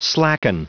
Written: slacken

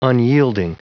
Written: unyielding

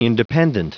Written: independent

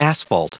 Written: asphalt

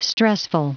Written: stressful

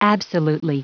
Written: absolutely

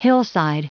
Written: hillside

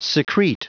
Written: secrete

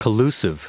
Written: collusive

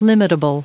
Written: limitable

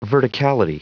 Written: verticality

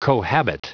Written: cohabit

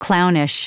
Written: clownish

